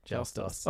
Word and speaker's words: Joustus: How do you spell that? Like joust and then ICA? – No, US at the end. Joustus. – Oh, interesting.